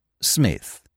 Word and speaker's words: Psmith: "Smith. The P is silent."